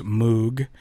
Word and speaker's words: Moog: His name is often mispronounced Moog, and as a testament to that fact, I'm going to play for you now a song off of the Mike Melvoin album, The Plastic Cow Goes Moog. Even though Robert Moog pronounced his name Moog.